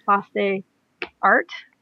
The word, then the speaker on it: Place des Arts: Is that what it's called? Place des Arts? – I think you're a better authority on the pronunciation than me, but yeah, Place des Arts.